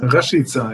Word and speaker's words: Rashica: Rakica oder wie heißt er? Rashica? – Rashica, ja.